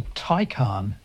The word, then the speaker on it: Taycan: I might have said a Taycan, but it's not that, is it? – No, it's a Taycan. – T-A-Y-C-N.